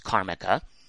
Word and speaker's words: Karmaka: It's spelled Karmica, like Karma with a K-A at the end, K-A-R-M-A-K-A. It's pronounced Karmica.